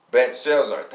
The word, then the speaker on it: Belshazzar: Belshazzar. Thank you. See, got some scholars in here. Belshazzar, after the name of my God and the spirits of the holy gods in him. So I said, Belshazzar, chief of the magicians.